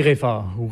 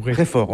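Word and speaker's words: Reford: Comment prononce-t-on bien ? – On dit Réfort. – Réfort, on ne dit pas le « de ».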